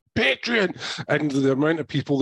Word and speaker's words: Patreon: Ian, would you really expect Cameron to pronounce it correctly? – It's pronounced Patreon.